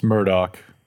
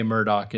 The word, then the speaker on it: Murdaugh: It's Murdoch. I know it is weird, right, that they spell it Murtaugh, but everyone does say Murdoch in the thing. – Well, they spell it Murdaugh, not Murtaugh. It's not true. Well, there's a D.